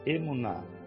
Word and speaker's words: emuná: Emuná. E-M-U-N-H. – É, é Emuná mesmo, é isso aí.